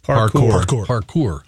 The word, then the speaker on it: parkour: Parkour.